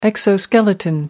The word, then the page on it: exoskeleton: American English